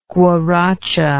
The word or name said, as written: guaracha